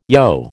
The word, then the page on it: yogh: American English